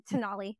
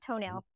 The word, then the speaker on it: Tonale: They have Tonale, not toenail. T-O-N-A-L-E. And it's really funny because they sent us the press. They sent us the press release and they're like, do not pronounce it toenail.